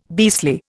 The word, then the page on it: beastly: Indian